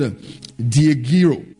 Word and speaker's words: diegeiro: The first one is the word diegiro. I'll spell it to you. D-I-E-G-E-I-R-O. Diegiro.